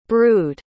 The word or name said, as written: brewed